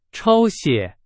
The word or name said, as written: 抄写